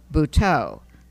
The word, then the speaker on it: Butoh: An important element of the plot was a modern form of Japanese dance that is called Buto, that's spelled B-U-T-O-H,